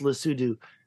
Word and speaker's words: Lesotho: This is Lesotho or Lesotho. It's pronounced Lesotho.